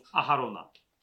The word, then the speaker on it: Aharon: Kochena, który siłą rzeczy musi być większy od największego z lewickich arcykapłanów Aharona. Czyli Aarona oni to wymawiają najwyraźniej. Aharon.